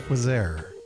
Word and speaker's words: Quezergue: Q-U-E-Z-E-R-G-U-E, but I have it on good authority. It's pronounced Quasar.